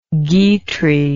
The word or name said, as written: guitry